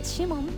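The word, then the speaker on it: 鰤門: 東京銀座と日本橋にある江戸前寿司のシモン、漢字でお魚のブリという字に建物の門と書きましてシモンと読みます。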